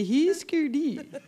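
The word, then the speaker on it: Hüsker Dü: Husker Du. – Husker Du. – If you pronounced all the umlauts, you would say Husker Du.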